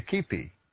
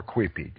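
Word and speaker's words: quipu: Their writing system involved thread and knots, and there are some very dedicated people who are learning to read the Quipi, or Quipi, depending on how you choose to pronounce it, which is a bunch of strings with knots and colors, and they tell stories.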